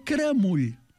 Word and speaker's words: caramull: Doncs a Riudoms, caramull es pronuncia cremull.